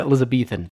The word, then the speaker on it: Elizabethan: In fact, in the Elizabethan? How do you say that shit? – Beethan. – Okay. Oh, Beethan. Thank you. – Yeah. – Elizabethan.